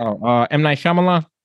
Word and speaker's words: Shyamalan: M. Night Shyamalan.